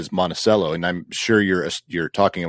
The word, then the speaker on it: Monticello: In some places, it's Monticello. – Monticello, correct. Yeah. – There's even one here in Tennessee. Anyway, and that one's pronounced Monticello, I believe.